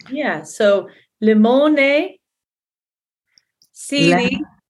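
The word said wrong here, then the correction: Lemoni (λεμόνι): Did I say it wrong again? – Lemoni. – Lemoni. So it's got that double E on the end.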